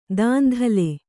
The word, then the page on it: ದಂದಳ: kannada; dandaḷa